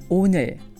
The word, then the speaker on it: 오늘: 오늘, 오늘, 오늘. So by the end of saying the word 오늘, you should find your tongue right behind your upper teeth.